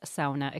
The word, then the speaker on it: sauna: Well, the way to go is sauna, as in the Finnish pronunciation. It's really the one Finnish word that is generally well-known in English, and so I think we should give tribute to them.